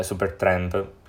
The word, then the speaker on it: Supertramp: Eh, proprio questo bicchiere di succo d'arancia Ehm, sarà poi riutilizzato come copertina di due compilation che si chiamano The Very Best of Super Trump, eh, Super Tramp più che altro si pronuncia,